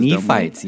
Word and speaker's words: Nephites: We're reading Tennis Shoes Among the, is it Nephites? How do you say this? – Nephites, yeah, good job.